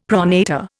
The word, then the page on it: pronator: Indian